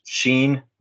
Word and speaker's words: Shin: And the third mother is pronounced Sheen, which is Hebrew for transformation.